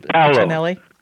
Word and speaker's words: Paolo Tonelli: Is it Paolo Tonelli? Help me with that. – Yeah, Paolo Tonelli. – Paolo Tonelli. Yeah.